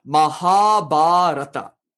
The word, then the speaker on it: Mahabharata: Now there are two long syllables. Remember in Sanskrit, it can be ha or it can be ha, ba or ba. So in that word, the ma, ra, ta are all short syllables. Ma, ra, ta. The ha and ba are long syllables. So when you pronounce that word, it's pronounced Mahabharata, you know. But we kind of drop the first guru and we just say Mahabharata. So when you consider linguistically what a guru is, the guru is the stress of any word.